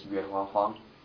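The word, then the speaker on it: Yuehua Huang: My name is Yuha Huang. – And how do we spell that? – Y-U-E-H-U-A, and the last name is H-U-A-N-G.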